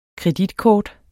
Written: kreditkort

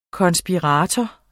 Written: konspirator

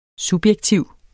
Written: subjektiv

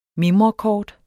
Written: mimrekort

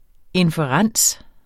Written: inferens

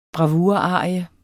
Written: bravurarie

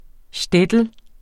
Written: shtetl

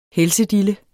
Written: helsedille

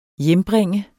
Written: hjembringe

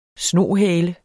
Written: snohale

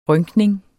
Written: rynkning

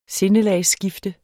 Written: sinde

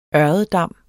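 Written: ØRREDDAMME

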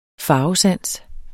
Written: farvesans

